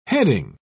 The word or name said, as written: heading